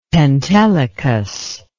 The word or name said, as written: pentelicus